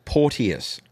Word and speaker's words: Porteus: It's Porteus, if you're going to pronounce it as everyone else does. But if you pronounce it properly, you're the first person to have ever done that.